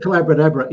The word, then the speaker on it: Collabradabra: Collabradabra, and it's the It's called Collabradabra. It rhymes with abracadabra because I was teaching collaboration.